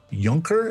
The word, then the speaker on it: Junker: I'm very pleased to be joined today by Joan Junker, or as it's pronounced in Germany, Junker. Is that right? Yes, Junker.